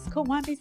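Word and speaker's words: Kawambee: My name is Kawambi, spelled with a K.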